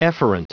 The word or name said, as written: efferent